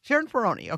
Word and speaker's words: Sheron Perrone: Sheron Perrone.